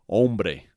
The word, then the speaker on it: hombre: So, the word of the day today is hombre. That's H O M B is in bravo, R E, that means man. Hombre. Notice the H is always silent.